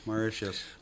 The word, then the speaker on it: Mauritius: I didn't know it was pronounced Mauritius. – Good for them. – And then what's number Mauritius. – One? – Mauritius. – That's how they say it, Mauritius? – Mauritius.